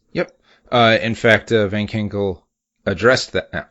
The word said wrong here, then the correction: Ginkel: I am just slaying that pronunciation here. It's Ginkel, Ginkel.